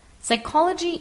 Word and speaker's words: psychology: That when we say the word psychology, we do not pronounce the P at the beginning. Psychology is the science and study of the mind and human behavior.